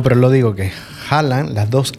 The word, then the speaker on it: Haaland: En Noruega las dos A juntas es O. Entonces Haaland.